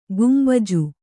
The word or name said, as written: ಗುಂಬಜ್